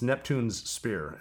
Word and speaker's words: Neptune's Spear: We just came out with Neptune's Beer to sort of commemorate Neptune's Spear, which is commonly mispronounced. It's Neptune's Spear, as in King Neptune's Spear. – Interesting. – People say Neptune Spear, but that's, you know, it's the two S's in a row.